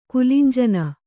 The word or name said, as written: ಕುಲಂಜನ